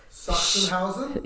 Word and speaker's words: Sachsenhausen: It was called, like, Sachsenhausen? – Yeah. That, you actually pronounced it really good just then.